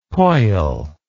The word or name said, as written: poil